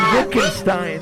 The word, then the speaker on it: Wittgenstein: Yeah, see, I knew how to pronounce the W in that. Wittgenstein.